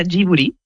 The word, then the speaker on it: Ghibli: En japonés se pronuncia Ghibli. Porque ellos no tienen el sonido de la L, pero es una historia bastante graciosa porque el nombre lo elige Hayao Miyazaki y lo elige por la palabra japonés.